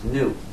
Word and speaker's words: GNU: The reason is, according to the dictionary, it's pronounced new.